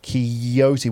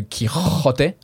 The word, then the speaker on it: Quixote: That sound, which in Spanish is a ch, so Quixote, like as if you're spitting up. But depending on where you are, or it could be also, I think, in Argentina, it's a sh. It's more a sh sound, or a ch sound.